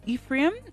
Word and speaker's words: Ephraim: Now the answer is the tribe of Ephraim.